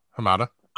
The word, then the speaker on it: Hamada: His name is Walter, and forgive me if I pronounce his last name wrong, Hamada, Hamada. – Sounds right. Hamada.